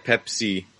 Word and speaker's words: Peipus: Yeah, and that's spelled P-E-I-P-U-S. So yeah. – Right. So Pepsis, it sounds like Pepsi, but yeah, it's spelled slightly different. – If you say it in Estonian, how Estonians pronounce it, it sounds exactly like Pepsi. So it's Pepsi, not Pepsi. So it's just one letter difference there.